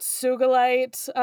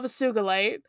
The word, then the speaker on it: sugilite: Sugalite is commonly pronounced with a soft G, as in ginger. We love this. Um, However, as with most minerals, its pronunciation is intended to be the same as the person it's named after. In this case, the Japanese name Sugi as a hard G is in geese.